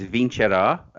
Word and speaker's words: Vincerà: Well, I mean, uh, just to, to give you a tip on the pronunciation, it's it's Vincerà, uh, which, go.